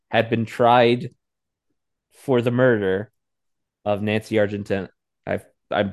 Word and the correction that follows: Argentino: It's Argentino.